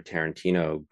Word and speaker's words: Tarantino: And, I mean, I don't think I knew another, other than my family, another Tarantino growing up at all. – Right. – No one could pronounce my last name really until Quentin showed up on the scene.